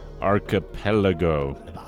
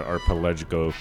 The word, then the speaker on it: archipelago: This is one of those words I always have trouble pronouncing. Archipelago. There you go. Sound it out. It's not an arpelago.